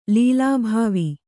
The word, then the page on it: ಲೀಲಾಭಾವಿ: kannada